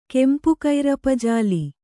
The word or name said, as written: ಕೆಂಪುಕೈರಪಜಾಲಿ